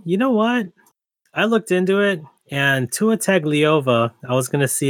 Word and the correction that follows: Tagovailoa: I believe they pronounce his name Tagovailoa, if I remember correctly. – Is it? – Tua Tagovailoa.